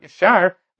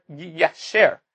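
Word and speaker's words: Yasher (יישר): which would mean that the proper pronunciation is not Yashar, but rather Yasher.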